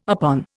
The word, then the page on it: upon: Indian